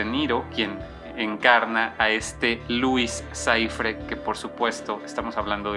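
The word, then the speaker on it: Louis Cyphre: se le conoce como Luis Saifre, que este nombre eh, pues es un juego de palabras que se pronuncia prácticamente como Lucifer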